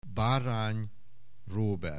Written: Barany Robert

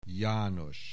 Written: János

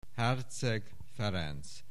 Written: Herczeg Ferenc

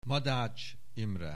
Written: Madách Imre

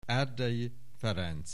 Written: Erdei Ferenc

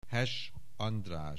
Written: Hess, András

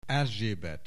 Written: Erzsébet

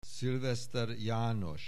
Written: Sylvester János

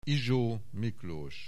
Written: Izso Miklos

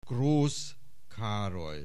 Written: Grosz Karoly

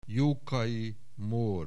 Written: Jokai Mor

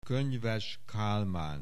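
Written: Könyves Kálmán

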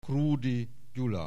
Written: Krudy Gyula

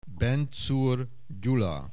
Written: Benczur Gyula

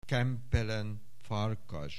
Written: Kempelen Farkas

